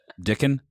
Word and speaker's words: Dickon: Dickon? – Rhymes with Rickon.